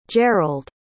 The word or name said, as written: jerald